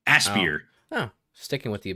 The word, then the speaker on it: Aspyr: Aspyr, yeah, Aspyr too.